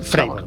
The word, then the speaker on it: Fravor: Fravor, vale. – Sería, sería la pronunciación en inglés David Fravor.